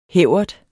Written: hævert

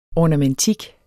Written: ORNAMENTIK

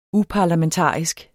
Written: uparlamentarisk

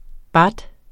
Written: baht

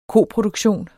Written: koproduktion

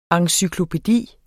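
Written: ENCYKLOPÆDIER

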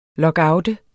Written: lockout

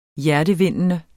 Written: hjertevindende

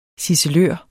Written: ciselør

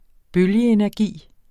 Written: bølgeenergi

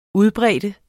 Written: udbrede

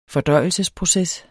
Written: fordøjelsesproces

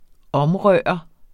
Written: omrøre

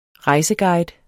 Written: rejseguide

